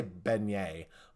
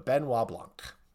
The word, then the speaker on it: Benoit Blanc: I always want to say Beignet, but Benoit Blanc.